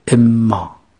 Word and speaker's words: اما: ف پس اما، اما در اصل بوده ان ما، نون و میم وقتی که در کنار هم قرار میگیرن نون تبدیل به میم میشه، میشه دوتا میم. اون دوتا میم در هم ادغام میشن، یکی میشن، مشدد تلفظ میشه، میشه اما.